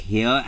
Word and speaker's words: hir: Hr, which is H-I-R. And Hrs. Which is H-I-R-S, which is actually, sorry, it's pronounced here and here's for the non-binary identifier.